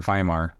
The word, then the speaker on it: Weimar: The Weimar Republic, or Weimar, if you're going to say it correctly.